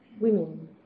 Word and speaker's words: women: Well, first, how to pronounce the plural? Women. Women. Even this is a short e.